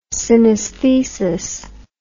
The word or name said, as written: synaesthesis